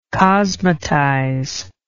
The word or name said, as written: cosmetize